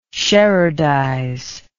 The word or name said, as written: sherardize